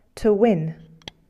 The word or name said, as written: win